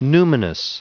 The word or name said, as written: numinous